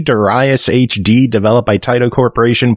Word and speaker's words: Darius: if you look at the Japanese pronunciation for it, then it is Darius,